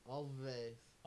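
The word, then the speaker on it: Alvvays: And the two V's are pronounced like a W. – Always.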